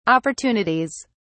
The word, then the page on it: opportunities: British English